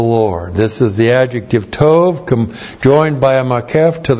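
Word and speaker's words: tov (טוֹב): T, long O, B, tov.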